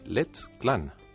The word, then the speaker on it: Let's Clan: Pero la iniciativa de la que hablamos hoy no es exactamente Clan, hay que pronunciarlo en inglés. Hablamos de Let's Clan.